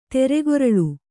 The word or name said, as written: ತೆರೆಗೊರಳು